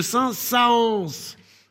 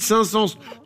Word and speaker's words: Saint-Saëns: Puisque je le rappelle, le rôle du tréma est de nous signifier que les deux voyelles ou syllabes qui se suivent se prononcent séparément. Donc logiquement, on devait prononcer Camille de Saint-Saëns. Alors qu'on dit Camille de Saint-Saëns, tout le monde s'en fout, mais c'est mon coup de gueule.